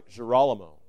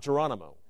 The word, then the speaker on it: Girolamo: I think it's Girolamo. Not Geronimo.